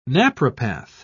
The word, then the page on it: naprapath: British English